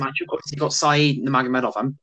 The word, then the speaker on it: Nurmagomedov: From the prelims, it is, you got Mark Striegel versus Saeed Nurmagomedov. Can't say his name. That one. Khabib's cousin. – Nurmagomedov.